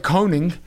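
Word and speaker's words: König: A doctor named Gerhard Koenig. He's 46 years old. K-O-N-I-G? Koenig. – No, that's probably right. This is like the way you pronounced it.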